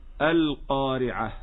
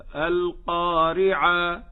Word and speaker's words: القارعة: كذلك نقول القارعة، وهذا خطأ منتشر بكثرة على الألسنة، يقفون على تاء التأنيث المربوطة بالألف بدلا من أن يقفوا بالهاء. نسمع هكذا القارعة ما القارعة ع، يجعلها بدلا من كونها هاء هكذا القارعة يجعلها ألفا، حتى نسمعها في بعض المحاريب يقولون سمع الله لمن حمدا، والصواب أنها هاء.